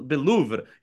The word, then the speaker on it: Louvre: I think you have to like Louvre. Roll the R at the end, right?